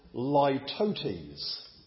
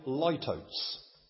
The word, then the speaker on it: litotes: Well, in fact, this is a figure of speech which is called litotes. Or if you want to pronounce it a different way, litotes. L-I-T-O-T-E-S.